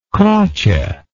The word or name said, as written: croce